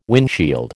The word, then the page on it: windshield: American English